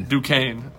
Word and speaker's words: Duquesne: Duquesne.